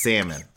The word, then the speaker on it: salmon: I don't trust or believe in anybody that pronounces the L in salmon. And love is not on the table, but salmon is. – Oh, yeah. No, yeah. No, he did it and the chef did it. I'm just like, it's salmon. The L is silent.